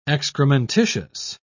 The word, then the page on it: excrementitious: British English